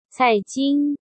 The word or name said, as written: Cai Jing